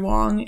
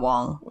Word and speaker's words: Huang: And, The only reason I at all know Andrew Huang is because... – Not Wong. Huang.